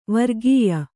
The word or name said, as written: ವರ್ಗೀಯ